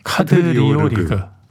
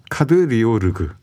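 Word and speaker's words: Kadriorg: 탈린에서 이제 가장 아름다운 궁전이라고 말하는 카드리오르그 발음이 좀 어렵죠? – 카드리오르그. – 카드리오르그. – 네. 르그.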